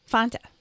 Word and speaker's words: Fanta: That's the way Europeans say it. Fanta.